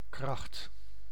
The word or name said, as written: force